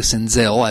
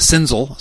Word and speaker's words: Senzel: And the first thing is we've learned to pronounce his name correctly, Senzel, as in Denzel Washington, instead of Senzel, which y'all called him originally.